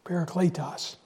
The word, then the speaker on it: parakletos: You need to put a long line above the E there because long E in Greek is an A, parakletos.